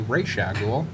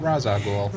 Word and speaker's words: Ra's al Ghul: And on the topic of mispronouncing comic book supervillains, I recently found out that it's actually Ra's al Ghul and not Ra's al Ghul.